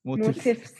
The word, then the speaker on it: Motifs: So you got to pronounce the name, say Motifs. – Motifs. – Yeah. – With the S.